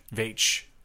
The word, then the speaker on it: Veitch: Veitch. – I think that's how you say it. – Is it V-E-I-T-C-H? – Yeah.